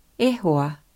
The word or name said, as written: e hoa